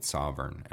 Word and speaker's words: Sovrn: and we spun that out and called it Sovrn, and I'm chairman of that company. – Spell that one. – S-O-V-R-N.